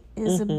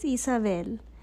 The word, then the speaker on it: Isabel: That's the gringo way of pronouncing it. Isabel. Mm-hmm. But it's Isabel.